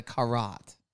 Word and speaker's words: carotte: By the way, he doesn't call his a carrot. He calls it a carotte. – That is the French pronunciation.